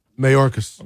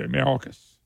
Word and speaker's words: Mayorkas: Matter of fact, Mayorkas. Am I saying this right? – Mayorkas. – Okay, Mayorkas.